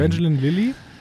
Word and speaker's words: Evangeline Lilly: Ähm, und um ihn herum seine Crew, also direkt hinter ihm auch prominent ähm, ähm, Evangelini, äh, äh, ich kann es ja nicht aussprechen. Evangeline Lilly, also so schräg Richtig, danke.